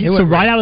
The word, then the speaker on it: Ridout: Riddell is spelled R-I-D-O-U-T. – You didn't see the long E? – There's no E. So if you're reading right out for the first time, it's R-I-D-O-U-T. How would you pronounce that? – I would go right out.